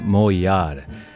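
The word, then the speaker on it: Moğollar: Right now we're listening to a band called Moyar. I've pronounced this name so wrong so many times because, you know, it's got that Turkish G, which is silent.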